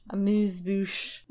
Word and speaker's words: amuse-bouche: The first course is, how do you pronounce this, a mousse Amuse-bouche? – Amuse-bouche.